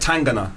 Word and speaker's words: Tangana: Tell me more about C Tang. Am I pronouncing this right? Tangana. – Yeah.